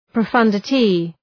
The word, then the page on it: profundity: prə’fʌndətı